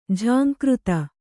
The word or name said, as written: ಝಂಕೃತಿ